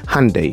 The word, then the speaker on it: Hyundai: Hyundai. – ¿Se pronuncia? – Hyundai.